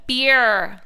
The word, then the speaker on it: beer: Because we say it like it's two syllables and it's not. Beer.